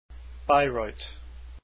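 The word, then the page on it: Bayreuth: biy -royt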